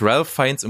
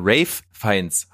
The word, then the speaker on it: Ralph Fiennes: Ich wurde äh, von Tony, äh, schönen Gruß an Tony, hingewiesen, dass Ralph Fiennes um Gottes Willen nicht so heißt, sondern Rafe Fiennes heißt.